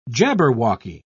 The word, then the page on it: jabberwocky: British English